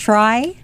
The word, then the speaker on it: TRI: I think it's pronounced try. – That is correct.